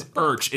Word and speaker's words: yrch: But he says Urch. It's spelled Y-R-C-H.